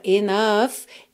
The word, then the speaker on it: enough: E lembra da pronúncia dessa palavra, enough. É N-O-U-G-H, enough.